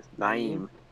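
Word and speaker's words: Nyheim: How do you say this guy's name? Naeem Hines? – Naeem. Yes.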